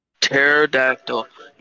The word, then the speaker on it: Pterodactyl: Pterodactyl. Yeah, the P is silent. The P doesn't even exist.